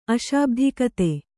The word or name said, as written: ಅಶಾಬ್ಧಿಕತೆ